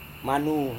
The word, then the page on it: manu: Serewen